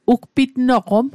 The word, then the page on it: ugpitnoqom: Mi'gmaq